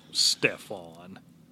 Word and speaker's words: Stefan: Stefan. – S-T-E-F-A-N. implosion. I don't know how to, you know, if I'm mispronouncing, I apologize. No, you're pronouncing it right, but he should be going by Steve.